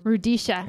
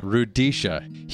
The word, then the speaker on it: Rudisha: Rudisha. – Rudisha.